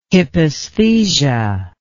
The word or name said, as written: hypaesthesia